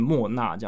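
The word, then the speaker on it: Mowna: 所以要念莫娜这样子。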